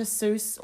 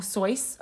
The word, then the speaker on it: Seuss: It's called, Dr. Seuss, or Soyce, I believe it's pronounced, Goes to War.